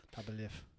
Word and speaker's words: Tapa Liv: It's T, yeah, pronounced, or the pronunciation is T-A-P-A-L-E-E-V. – It's a good. – Liv. Tapa Liv.